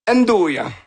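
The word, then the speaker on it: 'nduja: Il y a du, le truc dans le milieu, ça, c'est un truc qui se prononce très mal, c'est le Ndjuna? – Ndouya. – Bon. – Ndouya. – C'est pas loin.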